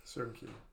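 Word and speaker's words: Söyüncü: And I think it's Soyuncu. – Right, Soyuncu.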